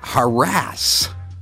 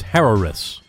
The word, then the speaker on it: Harris: Is it Harris or is it harass? – You got to pronounce both R's. It's Harris.